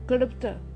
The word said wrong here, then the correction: क्लुप्त: i will check with the correct pronunciation one minute hold on klupta klupta klupta